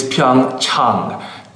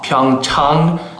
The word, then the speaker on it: Pyeongchang: The correct pronunciation is Pyeongchang. Chang, ah, ah, like when you go see your doctor, ah. Pyeongchang, Pyeongchang, Pyeongchang.